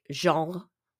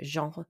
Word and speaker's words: genre: I know the pronunciation is more like genre, I think, genre in French, but in English, I'm sorry, we say genre.